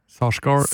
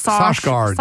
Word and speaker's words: Sarsgaard: Eh, og så har vi da to statsadvokater, eh, spilt av Peter Sargard og John Hugenacker. – Sarsgaard. – Sarsgaard. Sarsgaard. Altså vanskelig å uttale. – Peter Sarsgaard. – Sarsgaard, Med to a-er.